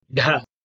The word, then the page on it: dh: Sanskrit